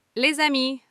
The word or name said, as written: les amis